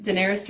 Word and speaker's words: Daenerys: So basically your first big cosplay was Daenerys? I'm bad at pronunciation, sorry. – Yep, Daenerys Targaryen from Game of Thrones was my first cosplay.